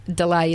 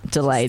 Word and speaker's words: DLA: So it's DLA.aus, but Delay is the name. – Delay. There we go.